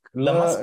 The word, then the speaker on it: Le Musk: I think it's pronounced Le Musk. Le Musk. Is how it's pronounced in French. And so the Le word is a French derivative.